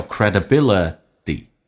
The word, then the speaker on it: credibility: 尖角的 R，稍微的嘴型没有像英式发音那么夸张，小一点点叫 trust Trust 跟 N 有个连叫 Trusten，气流不要进行阻塞，直接连音叫 Trusten， and 的 D 去掉，略音 Trusten credibility， credibility 指的是一个人的声誉信誉， credibility 美式发音最后一个 T 要并行浊化，叫 credibility is built T 去掉， is built slowly 比较饱满一点，先发再发 u，两个发音都要发出来， slowly, but can be lost almost instantly.